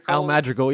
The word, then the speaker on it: Al Madrigal: Al Madrigal, yeah.